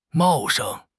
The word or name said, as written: 茂盛